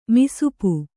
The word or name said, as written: ಮಸೆಪು